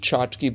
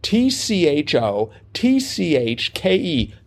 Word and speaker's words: tchotchke: It is spelled, listeners, T C H O T C H K E tchotchke. I think so. I think the first uh, the T is sort of silent.